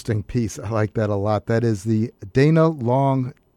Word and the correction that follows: Leong: Quintet, or I should say Dana Leong Quintet. I think that's how you pronounce it, from his album Leaving New York.